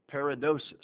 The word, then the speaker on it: paradosis: P-A-R-A-D-O-S-I-S. Looks like paradosis, but it's actually pronounced paradosis.